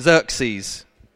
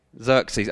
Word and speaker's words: Xerxes: And if you're not sure how to pronounce that name, Xerxes, okay? Xerxes, okay, so this is what happened during the time of Xerxes.